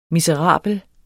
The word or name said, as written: miserabel